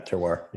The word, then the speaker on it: terroir: I think this description of terroir, is that how you say it? Terroir? Mm-hmm. – Yeah. Terroir. Yeah.